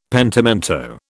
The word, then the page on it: pentimento: British English